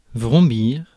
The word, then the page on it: vrombir: France; [vʁɔ̃.biʁ]